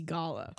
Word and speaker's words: Goller: And when I was in middle school and I ran for treasurer, I actually capitalized off the fact that my last name, when the way it's commonly is pronounced. – Like dollars. – If a dollar makes you holla, vote for Macy Gala.